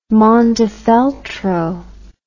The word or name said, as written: Montefeltro